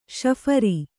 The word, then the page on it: ಶಫರಿ: śaphari